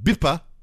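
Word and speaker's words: Bippa (ビッパ): Known in Japanese, pronounced Bippa.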